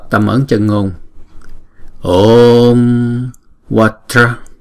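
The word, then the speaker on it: vajra: Chữ Vatra này là hai âm và mỗi âm là một nhịp ha. Vatra, Vatra. Vati, Vati. Vatra, Vati. Vatra. Chữ J này là J, J. Không phải, chúng ta không có đọc là Watara. Chúng ta đọc Watara là thành ba âm rồi, không?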